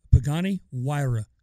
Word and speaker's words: Pagani Huayra: Pagani Huayra, because I looked up and I listened to it. That's the Italian pronunciation.